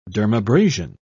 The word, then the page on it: dermabrasion: British English